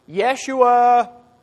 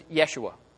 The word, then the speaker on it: Yeshua: That's an English version of a Greek version of his name. That's not what they called him. My pronunciation might be off, I'll be the first to admit, but they would have said something like, "Yeshua, Yeshua." In the Old Testament, we translate that Joshua.